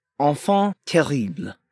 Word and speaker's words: enfant terrible: Enfant terrible. you have to roll your R. Just the enfant and terrible. Yeah.